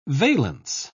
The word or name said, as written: valence